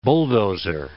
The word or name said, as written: bulldozer